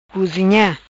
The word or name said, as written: Cozinha